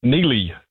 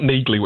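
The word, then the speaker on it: Neagley: It's the first spin-off from that monster action thriller series, and it focuses on his occasional partner, Neely, and it's not Neagly, which I thought it was as well, despite the hard G in her name.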